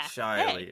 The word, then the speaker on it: Shaily: Shaylee? – Shaily, yeah. – Shaily, hey.